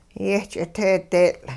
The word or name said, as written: yeh-ch'iteedeetL'